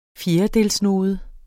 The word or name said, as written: fjerdedelsnode